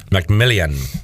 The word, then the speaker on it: McMillian: McMillan. That's how his name is spelled. There's an I. If you look it up, it's like McMillian. – McMillian.